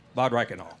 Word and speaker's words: Bad Reichenhall: Do I say this right? Bad Reichenhall, right? Close? Bad Reichenhall. Okay.